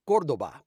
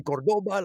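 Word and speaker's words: Córdoba: The word is actually pronounced Cordoba because it's from Spain. And for some reason, Chrysler wanted to Americanize it. And that must have been painful for him. I think he, he is from Spain to say Cordoba because it's actually Cordoba. The accent is on the first O.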